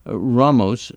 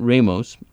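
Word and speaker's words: Jorge Ramos: And okay, so Jorge is pronounced, says Steve, Ramos, not Ramos, think mayor of Chicago. At least you didn't say George, Steve.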